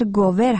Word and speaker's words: گوور: تلفظ درست شهر گوور هست که تصحیح می‌کنم و معذرت می‌خوام ازتون.